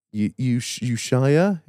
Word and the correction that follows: Ushuaia: I think it's in Ibiza. – Nah. – Ushuaia. – What? – Ushuaia. – Ushuaia.